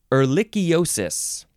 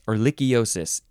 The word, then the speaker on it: ehrlichiosis: This one is ehrlichiosis. Yes, ehrlichiosis.